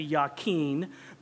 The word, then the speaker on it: Jachin: Now, the Hebrew pronunciation of Jachin is actually Yakin, but we're just going to pronounce the J of the English translation, okay?